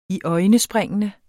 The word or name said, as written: iøjnespringende